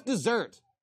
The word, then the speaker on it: desert: Desert is just like deserve. It's what they deserve. It's what their desert is.